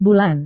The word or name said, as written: bulan